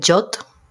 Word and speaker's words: JWT: es un estándar que se llama JWT, se pronuncia JOT, y lo que significa es un, JSON Web Token.